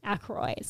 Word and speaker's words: Aykroyd: Aykroyd. Sorry. – Yeah. – I don't know how to pronounce his name. I wasn't being a jerk. I was just telling you how to say it. Aykroyd.